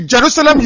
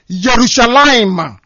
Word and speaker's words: Yerushalayim: The Jerusalem you mention, the real pronunciation is Yerushalayim. That is the name.